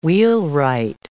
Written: wheelwright

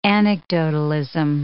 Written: anecdotalism

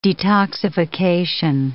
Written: detoxification

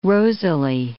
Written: rosily

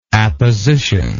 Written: appositionally

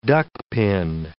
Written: duckpin

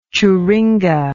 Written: churinga

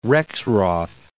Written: Rexroth

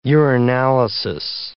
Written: urinalysis

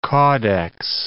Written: caudex

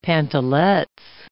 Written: pantalettes

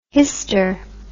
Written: hyster

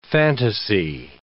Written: fantasy

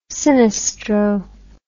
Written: sinistro

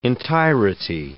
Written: entirety

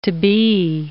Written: to-be

